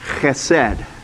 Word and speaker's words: chesed: I don't have a great Hebrew accent, but that is pronounced chesed.